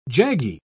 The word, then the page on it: jaggy: British English